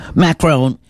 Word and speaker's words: Macron: Macron seem to be getting along very well.